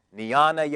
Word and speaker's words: jnana: They're called jnana yoga, spelled with a J, J-N-A-N-A, jnana yoga, which is usually practiced by the Brahmins.